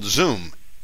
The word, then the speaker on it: Xoom: It's called Zoom, but spelled with an X. X-O-O-M.